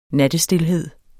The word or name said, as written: nattestilhed